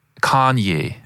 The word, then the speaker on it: Kanye: Kanye. – Kanye, finde ich, sagst du super. – Ich glaube, die Betonung ist auf der ersten Silbe.